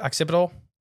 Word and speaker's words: occipital: Occipital? – Yep, occipital.